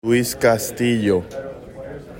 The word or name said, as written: Luis Castillo